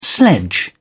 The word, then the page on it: sledge: British English